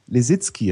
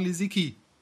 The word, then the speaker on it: Lisicki: aber die Eltern sind aus Polen, müsste es eigentlich Lisicki heißen. – Ja, aber alle sagen Lisicki.